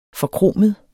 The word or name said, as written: forkromet